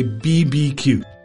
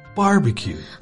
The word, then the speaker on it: barbecue: You never say BBQ. That is only written. When you see BBQ written, you still say barbecue.